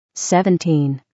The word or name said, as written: 17